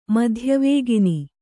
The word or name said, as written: ಮಧ್ಯವೇಗಿನಿ